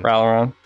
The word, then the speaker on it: Ralliron: Ralliron.